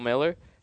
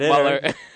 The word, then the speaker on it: Mueller: And I think first year hitting coach Bill Miller has been, I got his name right, right? – Yeah. – I didn't say Muller.